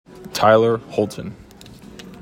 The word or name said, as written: Tyler Holton